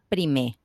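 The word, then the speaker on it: Primer: Iván le dijo, mira, en realidad es Universitat Jaume Prime, no se dice la R.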